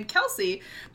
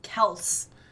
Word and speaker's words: Kelce: During the 2021 offseason, Kelce said that he pronounced his last name Kelce because that is the way his father pronounces it. Although the rest of his father's side of his family pronounces it Kels.